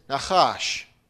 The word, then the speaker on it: nachash: The word serpent in Hebrew is nachash. By the way, that ch is a ch sound, right? Just like Nebuchadnezzar.